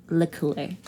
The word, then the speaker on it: La Croix: And you came in, and so we actually did a YouTube to find the pronunciation. We were right. It's La Croix.